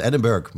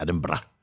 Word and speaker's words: Edinburgh: Omdat er staat, gewoon Edinburgh. Er staat Edinburgh, maar je zegt Edinburgh.